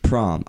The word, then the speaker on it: Pram: Prom.